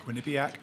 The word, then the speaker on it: Quinnipiac: Quinnipiac.